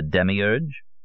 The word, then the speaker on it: demiurge: How do you say it? – You mean the demiurge? – That's him, yes.